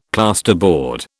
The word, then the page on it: plasterboard: British English